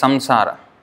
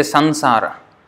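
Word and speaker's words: saṃsāra: In Sanskrit we would pronounce it as samsara. In Hindi they say sansara. So in Bengali they say Sonshar, Sonshar.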